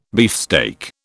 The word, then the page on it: beefsteak: British English